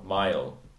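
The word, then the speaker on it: Mayall: Rick Mail. Mail? – Mile? – Mile. I really screwed that pronunciation.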